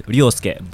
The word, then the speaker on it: Ryosuke: Ryosuke is actually my... Oh, if you actually call it through Japanese, it's Ryosuke. But, you know, Ryosuke is like the best English kind of equivalent to it.